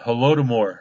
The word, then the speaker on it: Holodomor: But back in 1932-33, actually all the way up into 34, there was something in the Ukraine called Holodomor. There's two different pronunciations of that, but that's the one we're going to use for now.